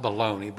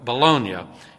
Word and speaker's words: Bologna: No baloney. It's not pronounced baloney, but Bologna.